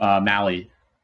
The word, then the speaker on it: Mahle: Is it Tyler Mahle? Is that how you pronounce it? – Yeah. – Reds pitcher? – Oh, Mally. – Mally? – Yeah.